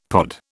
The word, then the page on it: pod: British English